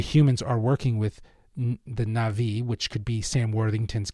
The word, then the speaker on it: Na'vi: You know, it seems like it's being instigated by both humans and the Na'vi.